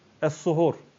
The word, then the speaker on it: es-suhur: Eğer o sin harfini ötreli, dammeli okuduğumuz zaman başka bir manaya geliyor. Es-suhur. Es-sahur ve es-suhur.